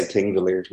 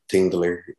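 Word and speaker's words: Þingvellir: The other day, Pingavallen, is that how do you pronounce it? – I'll try my best. So most people say Tingvellir, like Tingvellir.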